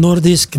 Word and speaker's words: Nordic: Y luego tenemos una pausa breve y nos veremos a las 6 de la tarde en Nordisk Black Theater. Nordic Black Theater, lo pronuncio en noruego, pero es Nordic.